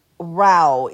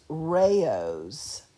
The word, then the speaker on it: Rao's: And it was Rao is what he called it, but that is not the pronunciation of it. It's Rao's, and